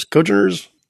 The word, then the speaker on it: congeners: And none of those nasty cogeners? – Congeners. Congeners. I hear it pronounced.